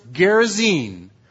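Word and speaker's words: Gerasene: Honey, it's pronounced Gerasen.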